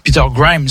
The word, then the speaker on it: Peter Grimes: Et Benjamin Britten, qui est un auteur contemporain du e siècle, est mort en 1976, par sa création Peter Grimes, notamment euh, Peter Grimes, faut prononcer correctement à l'anglaise,